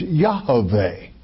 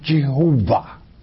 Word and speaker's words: Yahweh: When you go to the Hebrew manuscripts in Isaiah 12, 2, it's Yahweh, not Jehovah. Okay. There is no such word in the Hebrew tongue as Jehovah. You're mispronouncing the very sacred name. It is Yahweh.